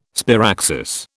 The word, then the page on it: sparaxis: British English